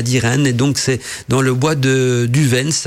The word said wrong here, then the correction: Devens: hein, j'espère que je le prononce bien, c'est de Devens plutôt. D-E-V-E-N, donc le bois de Devens, situé dans le canton de Neufchâtel, euh, canton suisse.